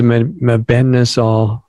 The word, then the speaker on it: mebendazole: So does the mebendazole, am I saying that right? – You're saying it perfectly, yes.